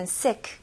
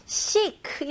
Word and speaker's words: sick: 여기서는 sick 이런 식으로 발음하시면 안 됩니다. 여기서 I 발음을 잘 해주셔야 되는데요. 네, 우리나라 사람들은 주로 I 발음 많이 어려워해요. 이거는 연습 혹은 연습을 많이 하실 수밖에 없어요. 네, 이거는 E 발음도 아니고 에 발음도 아니고 중간 발음으로 에. 에. 네, 제 입술 길게 이렇게 됐잖아요. 근데 E까지는 아니고 에. 에. 좋아요. 그래서 여기서는 sick. Okay, very good.